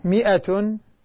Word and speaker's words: مئة: مئة هذه الألف لا تلفظ يا غادة، هذه الألف لا تلفظ، أيوه هذه الألف لا تلفظ يا بنتي، تكتب ولا تلفظ.